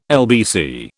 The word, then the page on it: LBC: British English